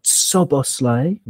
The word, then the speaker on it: Szoboszlai: They Imad Szoboszlai, note the pronunciation, as someone who's, you know, who will be missing.